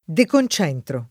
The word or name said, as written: deconcentrare